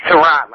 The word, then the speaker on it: Tehran: It's Tehran, like the city.